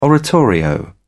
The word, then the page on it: oratorio: British English